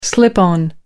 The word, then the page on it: slip-on: American English